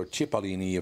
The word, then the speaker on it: Cipollini: Christian Cipollini, or Cipollini, if you want to go with the Italian pronunciation.